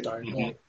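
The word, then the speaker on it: D'Arnot: Is that how you pronounce it? – Darnot. – Darnot? – Yeah, it's French.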